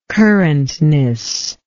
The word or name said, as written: currentness